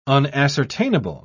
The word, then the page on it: unascertainable: British English